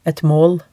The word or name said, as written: et maal